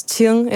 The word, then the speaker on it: Qing: Dat is Qing in het Mandarijn. En daar stond dan, ja, door heel veel leraren wordt het uitgesproken als King, want die weten niet wat ze ermee moeten.